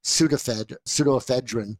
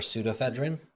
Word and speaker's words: pseudoephedrine: Why would it be P? For pseudoephedrine? – Well, I guess, yeah, you're right. – You think they're going to take a silent P and put it in size 48 font right on the box? – You would never guess I was valedictorian.